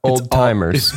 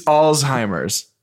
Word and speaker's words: Alzheimer's: Old timers. – Alzheimer's? – No, it's Alzheimer's. – You don't pronounce a T? – There's no T, so that's why I don't pronounce the T. – Alzheimer's? – Yeah. – I've always said Alzheimer's. – A-L-Z-H-E-I.